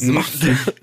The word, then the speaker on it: Mapfre: Nee, nee, es ist, Mapfre ist ein das schreibt man M-A-P-F-R-E. Offensichtlich ist es Mafre.